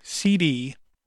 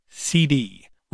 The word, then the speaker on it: Seedi: It's called CD, Retro Gaming System, but it's spelled S-E-E-D-I, CD, Retro Gaming System.